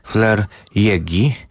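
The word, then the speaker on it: Fleur Jaeggy: To pisarka, której też nazwiska długo nie wiedziałem, jak wymawiać, ale wszystko wskazuje na to, że skoro urodziła się w Zurychu, to musimy z niemiecka, więc Flair Jägi.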